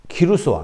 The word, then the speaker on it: 키루스: 그런데 이 키루스 왕, 그러니까 고레스 왕이라면 원래 이름 발음되면 키루스입니다.